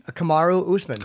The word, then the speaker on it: Kamaru Usman: Kamaru Usman.